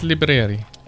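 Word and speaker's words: Libreri: And it's by Kim Liberi. Is that how you pronounce that? – Well, if it's Italian, it's Liberi.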